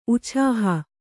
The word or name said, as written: ಉಛಾಹ